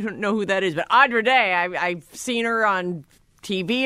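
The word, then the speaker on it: Andra Day: By the way, the artist's name is Audra Day, pronounced like Odd. – Oh, yeah. Audra Day. I know Audra Day. – It's not Andrea, and she is amazing.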